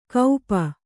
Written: ಕೌಪ

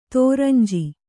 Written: ದುರಂಜಿ

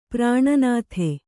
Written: ಪ್ರಾಣನಾಥ

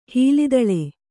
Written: ಹೀಲಿದಳೆ

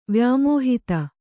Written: ವ್ಯಾಮೋಹಿತ

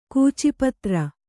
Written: ಕೂಚಿಪತ್ರ

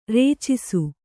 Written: ರಚ್ಚಿಸು

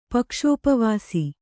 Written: ಪಕ್ಷೋಪವಾಸಿ

